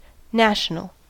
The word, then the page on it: national: American English